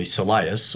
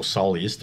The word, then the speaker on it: soleus: But interestingly, soleus or soleus, depending on how you want to pronounce it, is about eighty-five percent slow.